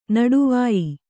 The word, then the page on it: ನಡುವಾಯಿ: naḍuvāyi